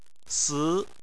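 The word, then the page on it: si2: tone 2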